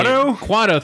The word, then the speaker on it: Quato: It's like Yoctu or Quatu, the dude inside the guy's belly. – Quato, thank you.